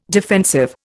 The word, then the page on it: defensive: Indian